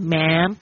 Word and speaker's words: ma'am: 哦，有时候呢，他们也会讲 ma'am，记得那个最后那个发音要把嘴巴闭上，ma'am，不要变成 man，man 就是男人，老兄，就是这种称呼方式，称呼朋友的方式。